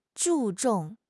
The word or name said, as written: 注重